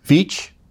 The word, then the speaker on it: Veatch: for documents involving work with a government contractor called Black and Veatch. I think that's how it's pronounced, V-E-A-T-C-H, involving work of any manner with biosafety laboratories in the country of Ukraine.